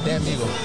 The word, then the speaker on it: Demigo: D-E-M-I-G-O, Dan Migo. – Okay. D-E-M-I-G-O, yeah. – Okay. – Yeah, a lot of people they pronounce it like Dan Migo or D Migo, but it's Dan Migo.